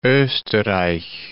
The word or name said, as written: osterreich